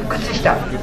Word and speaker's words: Kutsushita: Donc, la prononciation, ce n'est pas Kutsushita, Kutsushita, c'est Ku va être muet, Tsu va être muet, et Shi aussi, Kutsushita.